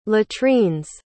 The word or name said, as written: latrine's